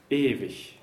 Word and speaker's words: ewig: ewig. – And there we have an IG that's not a K at all, it's the ich laut.